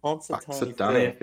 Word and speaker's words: Punxsutawney: Punxsutawney Phil. – Punxsutawney Phil.